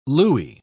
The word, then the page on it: looie: British English